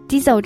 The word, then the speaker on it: diesel: 这里我们来看两个词，一个是汽油 gas，然后一个是柴油 diesel。 diesel 这个词的发音需要注意一下，它的这个 i e 发的是长元音一的音。